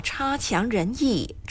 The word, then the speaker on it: 差强人意: 读作差强人意，差第一声没错。这里的差绝不是很差差劲的意思，好多人都误以为差强人意的意思是很差，品质或表现不好。